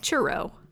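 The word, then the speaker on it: Cheiro: It's C-H-E-I-R-O. Chiro. – Churro.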